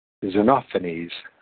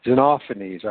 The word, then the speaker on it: Xenophanes: Instead of pretending that I know what I am saying, I will just find out from somebody else. Xenophanes. I was completely off. Xenophanes.